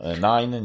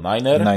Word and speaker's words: niner: Specyficzny sposób się wymawia nine, nie mówi się nine, tylko niner.